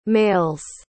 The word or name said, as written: mails